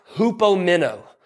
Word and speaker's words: hupomeno: It looks different than it sounds, but it's it's called it's pronounced hupomeno. Hupomeno.